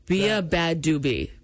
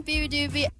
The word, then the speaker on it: beabadoobee: B-A-D-A-B-A-D-O-B-E-E. The word doobie's in there. Bia Badoubi. And that's not how you say it, because here is a clip of her saying her own name. – Hi, I'm Bia Badoubi.